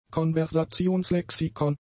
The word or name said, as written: Konversationslexikon